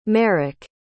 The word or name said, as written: marek